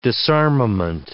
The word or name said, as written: disarmament